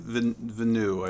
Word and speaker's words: Villeneuve: Is that how you say his name? – Villeneuve, I believe. Yeah.